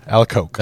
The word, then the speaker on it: Alacoque: Do you pronounce the extra Q on the end of that? Alacoque? How do you say that last name? – I do, yes. – How do you say it? – Alacoque. – Alacoque. – That's how I've always pronounced it.